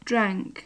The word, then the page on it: drank: English